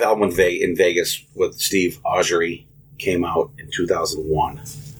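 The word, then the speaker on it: Augeri: Augery. It rhymes with Steve Perry.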